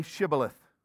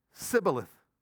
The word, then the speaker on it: Shibboleth: When he said no, they said to him, "Then say Shibboleth." And he said, "Sibboleth," for he could not pronounce it right.